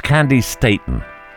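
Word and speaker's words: Candi Staton: So I guess for about 30 years I've been mispronouncing that poor lady. Roger Day took me on one side and he said, "Simon, please." And I said, "What? What have I done?" And he said, "It's Candy Staten."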